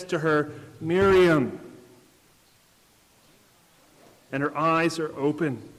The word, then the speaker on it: Miriam: But when Jesus speaks her name here in the Greek, it actually gives the Aramaic pronunciation of her name. Miriam.